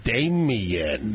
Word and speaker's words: Damien: Damien.